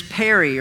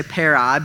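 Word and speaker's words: peri (περί): And that word concerning, the way we pronounce the Greek word is peri, or some say peri, but it's P-E-R-I.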